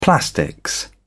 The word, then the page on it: plastics: British English